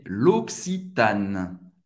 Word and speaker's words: L'Occitane: L'Occitane em francês, é l'Occitane. L'Occitane. L'Occitane. Você vai fazer uma pausa entre o primeiro C e o segundo C. Loc, Loc, Si, Tan, Acabanoen. Non vai pronunciar e no final.